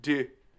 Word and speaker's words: Deux: Deux. It's French. You just swallow like most of the letters. You just duh.